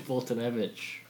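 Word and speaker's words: Foltynewicz: Fultonewicz. That is how you say it.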